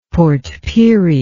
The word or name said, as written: Port Pirie